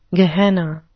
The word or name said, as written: gehenna